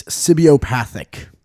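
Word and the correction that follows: cibopathic: Sibio. – It's spelled like C-I-B. – Or I'm sorry, sibopathic.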